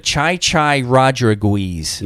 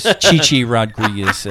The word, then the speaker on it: Chi Chi Rodriguez: One of my favorite, when he did the Les Nessman comment, one of my favorite Les Nessman's mispronunciations is Chai Chai Rodriguez. – Yes. – Which is Chi Chi Rodriguez, a golfer.